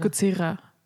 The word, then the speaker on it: Godzilla: Ja, genau, es ist Godzilla und nicht Godzilla. – Ich habe es eher wie ein englisches R, so wie ein R ausgesprochen immer. – Godzilla. Ja, Godzilla. Ja, es ist so mit ein bisschen Zungenschnalz.